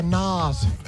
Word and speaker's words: Nas: Yeah, Nas.